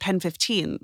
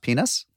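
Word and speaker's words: PEN15: Is that how you say the name of that show? It's not just pronounced penis? – No, it's called Pen 15.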